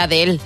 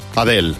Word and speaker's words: Adele: Yo, claro, diría Adele. – Adele.